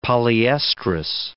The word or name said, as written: polyestrous